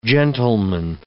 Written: gentleman

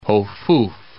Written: Hofuf

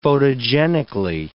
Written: photogenically

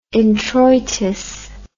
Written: introitus

